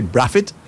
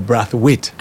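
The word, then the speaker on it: Braithwaite: Now, I've always wondered, that last name, Braathwaite, is that pronounced, we usually say Braffitt or Braathwaite? – I'm not sure. – No, people, I have friends and they say Braffitt. – Yes. – Oh. Why, from a child, I say Braffitt. – Yes. – But people pronounce theirs Braathwaite. So if you pronounce it Braathwaite, I'm going to call you Braathwaite. – Right. – But if you pronounce it Braffitt, I'm going to call you Braffitt.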